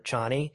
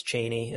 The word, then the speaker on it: Chani: that we call her Chani. – Really? – Because I think technically it's Cheney is the correct pronunciation,